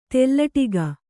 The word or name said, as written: ತೆಲ್ಲಟಿಗೆ